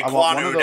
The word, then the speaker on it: Ekwonu: Ekwonu, I think is how you pronounce his name. – Yeah, Ekwonu, that's the dude who like Lowell Litchmore completely like blew up the chats with.